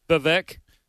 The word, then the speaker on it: Vivek: Vivek.